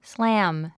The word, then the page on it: slam: slǽm; スラァム